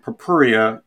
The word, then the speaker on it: purpurea: But it's Sarracenia is the genus name, and Purpurea is the species name.